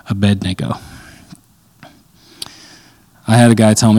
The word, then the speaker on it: Abednego: Anyway, Abednego is how you pronounce that.